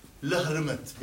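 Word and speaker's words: Lhermitte: L-E-H-R-M-E-T Lermet بينكتب لكن تلفظ Lermet.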